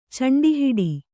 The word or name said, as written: ಚಂಡಿಹಿಡಿ